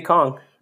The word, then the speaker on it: Kong: Is it supposed to be King? Did they spell it wrong? – No, it's supposed to be Kong.